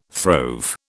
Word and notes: throve: British English

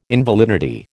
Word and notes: invalidity: American English

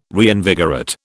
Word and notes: reinvigorate: British English

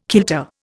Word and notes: kilter: Indian

